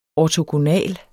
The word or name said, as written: ortogonal